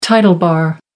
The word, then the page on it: title bar: American English